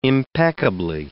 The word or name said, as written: impeccably